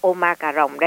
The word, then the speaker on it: Omicron: Và bây giờ thì tiếp diễn với cái con mà Omicron mà có người đọc là Omicron đây.